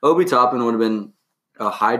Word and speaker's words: Obi Toppin: Obi Toppin.